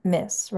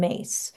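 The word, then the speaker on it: Myss: So the person that I'm talking about is medical intuitive Carolyn M Miss, right? Carolyn Mace. It's really pronounced Mace. A lot of people pronounce it Miss because it's M-Y-S-S, but it's Mace.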